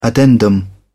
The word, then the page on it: addendum: British English